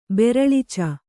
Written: ಬೆರಳಿಚ

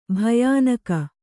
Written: ಭಯಾನಕ

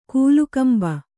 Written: ಕೀಲ್ಕಂಬ

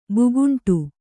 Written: ಭೂಖಂಡ

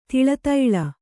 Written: ದಾಳಾದಾಳಿ

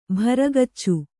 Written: ಭರಗಚ್ಚು

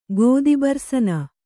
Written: ಗೋದಿಬರ್ಸನ

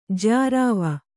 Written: ಜ್ಯಾರಾವ

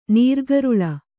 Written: ನೀರ್ಗರುಳ

